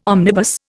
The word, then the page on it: omnibus: Indian